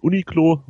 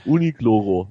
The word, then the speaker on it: Uniqlo: Und weil die Japaner das R ähnlich wie eines L aussprechen, ist es Uniqlo. Du musst das R so ein bisschen rollen.